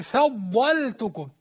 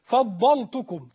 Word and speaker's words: فضلتكم: خلي بالك من كلمة فضل، اللام دهيا بعض الناس بينطقها يقول إيه فضلتكم فضل، لا لاحظ ترقيق اللام لأنها مجاورة لحرف استعلاء مطبق، مستعلى مطبق هو الضاد، فما تقولش فضلتكم، لازم تاخد بالك بالفاء مرققة والضاد مفخمة واللام مرققة، تقول إيه وأني فضلتكم على العالمين.